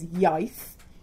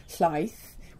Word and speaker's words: Iaith: but so the language of Tyrrhenial is Iaith. I'm constantly pronouncing that as Llaith, which means milk in Welsh, because I'm bad at Welsh.